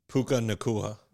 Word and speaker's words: Puka Nacua: Puka Nacua.